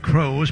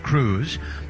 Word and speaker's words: Croes: At first base, Dayson Cruz, spells it C-R-O-E-S, looks like Crows, but it's pronounced Cruz.